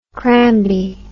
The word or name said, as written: crambe